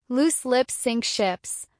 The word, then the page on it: loose lips sink ships: American English